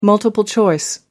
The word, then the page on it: multiple-choice: American English